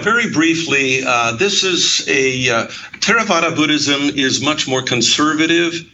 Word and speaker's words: Theravada: And I'll take a shot at pronouncing it Theravada, but that, I'm sure I've got that wrong. – You got it right.